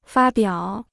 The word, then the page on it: 发表: fā biǎo